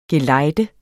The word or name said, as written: GELEJDENDE